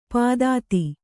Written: ಪಾಥೋದಿ

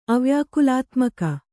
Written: ಅವ್ಯಾಕುಲಾತ್ಮಕ

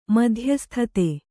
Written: ಮಧ್ಯಸ್ಥತೆ

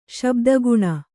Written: ಶಬ್ದಗುಣ

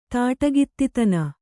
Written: ತಾಟಗಿತ್ತಿತನ

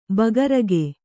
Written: ಬೇಗುರಿಕ

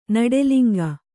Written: ನುಡಿಲಿಂಗ

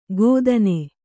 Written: ಗೋದನೆ

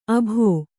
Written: ಅಬ್ಬೊ

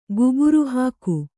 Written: ಗುಬುರುಹಾಕು

